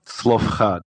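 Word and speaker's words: Zelophehad: Quick, how do we pronounce that? – Slofchad.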